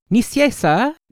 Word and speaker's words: Nicieza: Oh, see, I've been saying it wrong for 2 hours. Nicieza? Did I get it closer that time? Was that any better? – You got much.